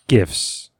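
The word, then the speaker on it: GIF: I say, I say GIFs. Hard G.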